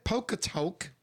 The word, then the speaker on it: Polka Tulk: Listen, you know the polka tuck. How do you say it? Polka tulk. – Yes. – Polka tulk blues band. – Yeah, that's right.